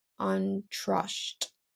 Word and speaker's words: Antrosht: In Ethiopia, they have a three day celebration honoring motherhood in mid fall after the rainy season, and it's called Antrosht.